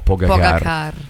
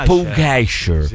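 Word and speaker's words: Pogačar: Comunque, visto che ci tiene la pronuncia esatta dei nomi, si pronuncia Pogacar, no Pogacar. – Pogacar. – Si pronuncia Pugacher.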